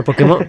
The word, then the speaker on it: Pokémon: Pero Pokémon, Pokémon tiene acento en la E.